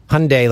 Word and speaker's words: Hyundai: Hyundai, like Sunday. – Exactly. That's. – So I guess I should know how to say the. – We've been saying it wrong the whole time. – Mr. O'Toole, it's Hyundai.